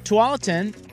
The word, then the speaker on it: Tualatin: It was the Jensen brothers, not the Bryan brothers, the Jensen brothers, the tennis brothers who pronounced it Tulleton on the radio. That's our final answer on that. At any rate, X Golf Tualatin.